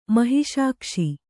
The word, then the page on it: ಮಹಿಷಾಕ್ಷಿ: kannada